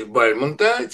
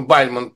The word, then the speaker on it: Бальмонт: Это, как мы понимаем, Эдгар По в переводе Бальмонта, те самые Бальмонт, правильное ударение, а не Бальмонт, те самые колокола.